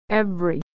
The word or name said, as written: every